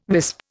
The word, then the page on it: wisp: Indian